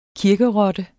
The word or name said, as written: kirkerotte